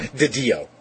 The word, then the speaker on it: DiDio: To quote Dan, I'm sorry, how do you pronounce his name? – DiDio. – DiDio? – Yes.